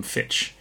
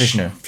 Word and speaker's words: Fichtner: Fichtner. – Fichtner. I didn't finish typing up his name when I was doing it. That's fine. William Fichtner.